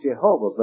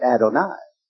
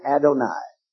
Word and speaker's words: Adonai: So that any Jew seeing that word, as we're looking at it right now, would pronounce it not Jehovah, but Adonai. And that's the way they do it today. They will not attempt to pronounce that. Seeing those vowels, they would simply pronounce it Adonai.